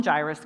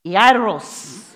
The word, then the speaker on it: Jairus: His name is pronounced like this. Say E, I. Now you're going to roll your R. Ros. Jairus.